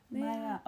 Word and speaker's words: Maya: What? Don't they say Mia? Maya? Mia. My Lisa. My, but they, it's spelled in the book M-E-Y. – Yeah, my. It's Maya Lisa. – Maya. Okay, anyways.